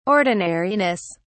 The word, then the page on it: ordinariness: British English